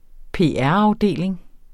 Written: PR-afdeling

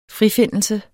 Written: FRIFINDELSER

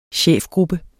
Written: chefgruppe

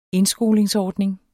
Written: indskolingsordning

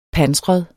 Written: pansret

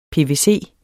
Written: pvc

